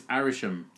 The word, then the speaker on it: Arishem: It's Arishem. – Arishem. – Okay, there we go. What were we saying, Aramesh? – We were saying Aramesh, so we got the last two syllables. We got the last syllable the wrong way around.